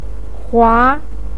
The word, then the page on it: hua2: pinyin